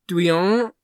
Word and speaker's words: Doillon: So he's like Jacques Douillon or something. Mm. It's spelled D-O-I-L-L-O-N. – Okay. – And the L-L-O definitely makes it, it's like a yon at the end. – Mm-hmm. – But I don't remember what the D-O-I does.